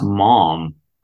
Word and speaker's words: Maugham: Somerset Maugham. A novel that the New York Times, called, quote, surprisingly talky. – I think it's actually pronounced mom.